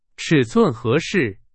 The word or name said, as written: 尺寸